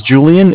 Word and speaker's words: Julian: my name is Julian. It's J-U-L-I-A-N. – Uh-huh. – I'm calling with Bank of Cardiff. Cardiff is spelled C-A-R, D as in David, I-F-F, as in Frank.